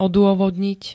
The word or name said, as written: odôvodniť